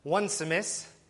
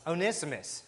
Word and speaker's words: Onesimus: I called him Onesimus, and then I made sure to double-check that because I was told I was wrong in my pronunciation. It's Onesimus, so there we are.